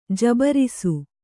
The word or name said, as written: ಜಬರಿಸು